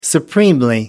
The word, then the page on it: supremely: American English